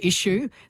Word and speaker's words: issue: And we know from spellings in the 1500s, spellings like ISHOO, issue. So it was pronounced that way back then.